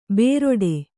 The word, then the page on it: ಬೇರೊಡೆ: bēroḍe